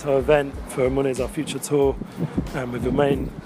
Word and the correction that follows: Uman: Uman, Ukraine.